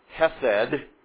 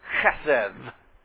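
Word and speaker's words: chesed (חסד): First of all, you will hear it maybe spoken as chesed, and that's not really how we want to pronounce it, all right? This is chesed.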